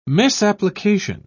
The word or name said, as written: misapplication